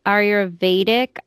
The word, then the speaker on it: Ayurveda: It was for, I don't know if I'm saying this right, Ayurveda.